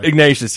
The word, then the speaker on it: Ignatius: And then the article you told me about the other night that I didn't even know about from David Ignatius, who's a constant guest on Morning Joe. – I had a terrible time trying to pronounce his name yesterday. – Ignatius, yeah. Well, I mean, I do because I've watched the program.